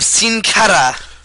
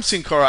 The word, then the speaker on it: Sin Cara: My smart moment of the week is the return of Sin Cara.